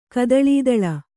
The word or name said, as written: ಕದಳೀದಳ